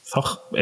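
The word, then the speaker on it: Zoch: Zox ¿Cómo se pronuncia? – Eh, Zog. – Zog. Eh, z o c pero como en alemán esas cosas son raras de pronunciar, pues. Bueno, pues Zock ¿vale? que es la, la editorial Madrid, es un juego creado por Thomas Lieching.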